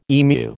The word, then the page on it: emu: American English